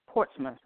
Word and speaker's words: Portsmouth: Scheduled to go to Arkansas, Shreveport, Portsmouth, Virginia, Portsmouth, that's how you say it, Virginia, Chesapeake.